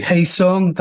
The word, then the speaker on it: Hae Sung: So when I was observing uh, the, the central relationship between Nora and I just want to pronounce this correctly. Hae Sung, that's the Perfect. correct. Okay, excellent.